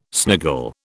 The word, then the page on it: sniggle: British English